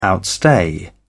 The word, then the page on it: outstay: British English